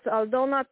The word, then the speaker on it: Aldona: Yes, Aldona Kumpin.